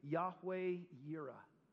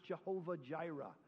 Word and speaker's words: Yahweh Yireh: And Abraham named the place Yahweh Yireh, which means the Lord will provide. Some of you have heard it pronounced Jehovah Jireh. That comes from a German translation.